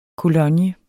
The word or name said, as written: cologne